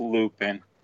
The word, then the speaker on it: Lupin: No, it's Lupin. That's still the same thing.